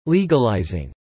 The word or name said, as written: legalizing